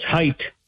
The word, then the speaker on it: height: It's height, which rhymes with kite, not hype.